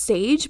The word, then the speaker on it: Saje: It was like a wellness store, and I believe it's pronounced Sage, but it's spelled S-A-J-E, and it's in Malibu, California, is their storefront.